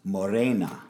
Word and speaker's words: morena: Now, I think in Espanol it is pronounced Morena.